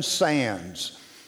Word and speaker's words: Sandys: His name is Jonathan Sands, and it's spelled S-A-N-D-Y-S. So I encourage you to look that name up. Google that name, Jonathan Sands, though you would spell it Sandys, because he's from England, and I'm not going to try to talk like he does, but they talk kind of funny, you know.